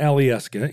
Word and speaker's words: Alyeska: There's Mount Alyeska in outside of Anchorage in Girdwood, Alaska. – That sounds like somebody pronounced Alaska in a fancy way.